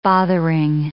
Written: bothering

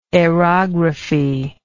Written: aerographical

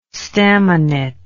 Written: staminate

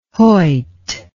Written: Hoyt

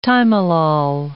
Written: timolol

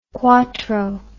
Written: cuatro

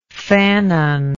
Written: Fanon

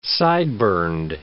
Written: sideburned

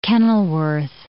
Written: Kenilworth

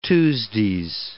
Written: Tuesdays

